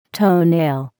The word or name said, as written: toenail